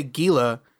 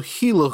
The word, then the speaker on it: Gila: Instead of Gila, because they say Gila. And then they, no, it's with a soft G. It's a Gila Hooligans.